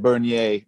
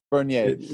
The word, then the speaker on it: Bernier: And just for anyone listening, you just said Bernier, not Bernie. I think because when you first said it, I thought, did he just say Bernie? But Bernier. – Bernier, yeah. That's my, I pronounce it in English, Bernier in French, Maxime Bernier.